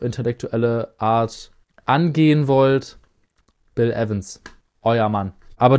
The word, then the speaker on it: Evans: Bill Evans. – Zumindest wird er halt im Intro verwendet. – Es wird Evans ausgesprochen.